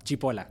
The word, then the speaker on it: Cipolla: Did I pronounce that correctly? Or Cipolla? – No, Cipolla. – Cipolla, it's Italian. – It's Italian. It's okay. Like, it's onion in Italian.